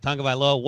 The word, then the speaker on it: Tagovailoa: Tagovailoa, well done there.